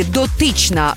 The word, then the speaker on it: дотична: Наголос на другому складі «дотична» – значення таке.